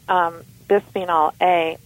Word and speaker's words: Bisphenol A: Well, the one that comes to mind off the top of my head is bisphenol A, although that is currently controversial, but it is very, very widely spread throughout many kinds of products.